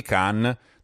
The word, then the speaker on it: Cannes: come esempio al Festival di Cannes, notare la pronuncia con l'accento in fondo,